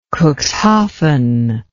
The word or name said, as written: Cuxhaven